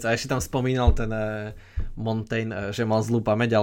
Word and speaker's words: Montaigne: Ale na toho Montana, e, ak neviem, ako sa to vyslovuje, na toho... – Montaigne. – Na toho Montaigne by som povedal, že e, vidíš, že poruchy sústredenia existovali už aj koncom 16.